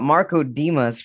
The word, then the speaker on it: Marco Dimas: I've got Marco Dimas from Los Angeles, California.